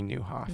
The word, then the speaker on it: Nieuwhof: So his name is spelled N-I-E-U-W-H-O-F, Kerry Newhoff.